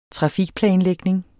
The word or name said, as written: trafikplanlægning